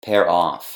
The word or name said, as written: pair off